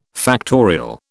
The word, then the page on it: factorial: British English